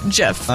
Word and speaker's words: GIF: And I'm sticking with Jiff, Just like just like jelly, it's Jiff.